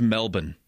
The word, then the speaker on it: Melbourne: It's Melbourne. There's the R is Melbourne.